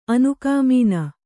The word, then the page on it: ಅನುಕಾಮೀನ: anukāmīna